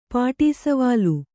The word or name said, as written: ಪಾಟಿಸವಾಲು